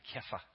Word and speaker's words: Kepha: I think in Aramaic they pronounce it Kepha.